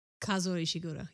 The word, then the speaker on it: Kazuo Ishiguro: Yes, Kazuo Ishiguro, yes.